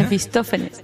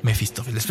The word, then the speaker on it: Mefistófeles: Y un abrazo a todos, a Langley, a Cherry, a, a, Curie y a Mefito, ¿cómo se dice? Mefistófeles. – Mefistófeles.